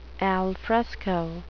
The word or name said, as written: alfresco